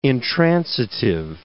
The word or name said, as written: intransitive